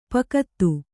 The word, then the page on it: ಪೋಕ್ತ: Kannada